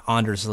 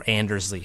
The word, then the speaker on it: Anders Lee: So yes, John Tavares had a positive impact impact on Josh Bailey, but Josh Bailey had a positive impact on Anders Lee or Andersley, however you want to pronounce it. I do Anders, so, so I sound Anders.